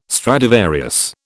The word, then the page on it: Stradivarius: British English